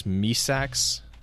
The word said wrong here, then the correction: Mithrax: I always mispronounce it. It is, because that's what she called him. – Well, that's actually his name. That's actually his name. She gives him the name Mithrax.